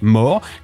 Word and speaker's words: Moore: En 1973, c'est Roger Moore, oui, ça se prononce Moore, qui endosse le costume mythique au moment même où le premier choc pétrolier plonge le monde dans la crise économique.